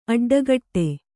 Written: ಅಡ್ಡಕಟ್ಟು

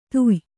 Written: ಧಾಯ್ಯೆ

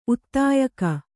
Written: ಉದ್ಯೋಗ

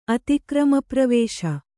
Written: ಅತಿಕ್ರಮಪ್ರವೇಶ

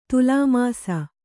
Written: ತುಲಾಮಾಸ

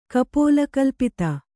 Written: ಕಪೋಲಕಲ್ಪಿತ

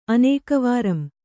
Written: ಅನೇಕವಾರಂ

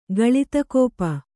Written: ಗಳಿತಕೋಪ

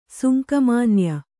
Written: ಸುಂಕಮಾನ್ಯ

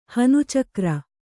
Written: ಹನುಚಕ್ರ

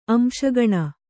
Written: ಅಂಶಗಣ

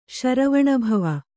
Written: ಶರವಣಭವ

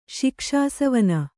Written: ಶಿಕ್ಷಾಸವನ

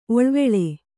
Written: ಒಳವಾಲ

